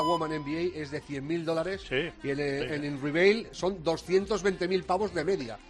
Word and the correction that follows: Unrivaled: O sea, pronúncialo bien, que estamos... a ver, Unrivaled. – No es una palabra fácil, ¿eh?